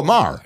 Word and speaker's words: Maher: Amar, uh, it's HBO. Oh, Maher. Maher.